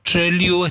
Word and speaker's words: Trelew: Se escribe Lewis, claro. – Por eso se llama Tre, que es pueblo. Liu, Trelew es Trelew, el pueblo de Lewis.